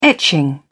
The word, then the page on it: etching: British English